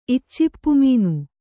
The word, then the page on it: ಇಚ್ಚಿಪ್ಪುಮೀನು: iccippumīnu